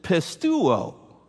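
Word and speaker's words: pistuo (πιστεύω): He never uses that word once, but he always uses the verb word for faith, which is pistuo. I pronounced that right. I practiced that a dozen times yesterday.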